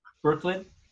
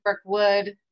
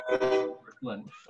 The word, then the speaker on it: Birkeland: Birkeland? – Norwegian. I get all kinds of things. People say Birkeland, Birkwood, but it's Birkeland. – Birkeland.